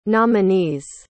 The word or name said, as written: nominees